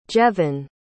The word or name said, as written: jevin